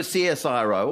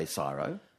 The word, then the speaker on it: CSIRO: Well, you can either do a CSIRO or you can, some people say CSIRO.